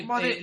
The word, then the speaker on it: Bode: Que aquí como no sabíamos pronunciarlo Body en castellano, él es se dice Body, le llamamos Bode.